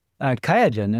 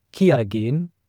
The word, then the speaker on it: Qiagen: And it was called Diagen back then, Diagen, and then they formed it to Qiagen, the German way to pronounce it, but internationally it's pronounced Qiagen.